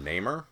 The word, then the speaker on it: Namor: Did you call him Namor? – Namor. – Thank you. – Say it like you mean it. That's true. Like you know how to pronounce it.